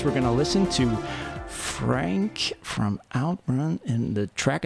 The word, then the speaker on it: FRNK: So we had Heartbeat here on Relative Ability, and the next song is FRNK, pronounced Frank, Outrun City.